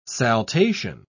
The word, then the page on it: saltation: British English